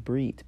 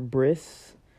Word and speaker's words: brit: In Hebrew, I think it's pronounced brit, B-R-I-T, or bris, B-R-I-S. I think Lubavitch, or I think Lubavitch people pronounce their T's as like an S. And so, or maybe it's like Chabad, I don't know who it is, but there's an ultra-Orthodox group that pronounces the T's as S's. And here they have it written in English letters, B-E-R-I-T-H, instead of B-R-I-T or B-R-I-S.